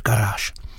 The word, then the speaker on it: Garage: Od roku 93 hrající s upraveným názvem Garage, ale vyslovuje se to samozřejmě pořád Garáž.